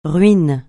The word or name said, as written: ruine